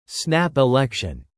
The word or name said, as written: snap election